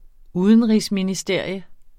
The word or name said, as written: UDENRIGSMINISTERIET